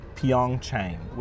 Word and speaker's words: Pyeongchang: How do you pronounce Pyeongchang was the episode title.